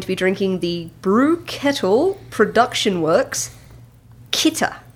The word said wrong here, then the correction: Kitka: Kitka? – Yeah, there we go.